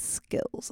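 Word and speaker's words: sKILLZ: Lo and behold, it became a little bit of a prophecy because I do have mad skills, like in a lot of different things.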